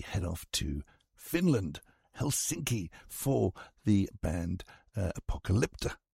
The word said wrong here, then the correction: Apocalyptica: Hang on, get it right. Apocalyptica.